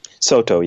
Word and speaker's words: Soto: Soto, yeah.